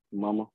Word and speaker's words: Muma: like a Dean or a Muma, right? That's how you say his name? – Mama. – Mm-hmm. Mama, yeah.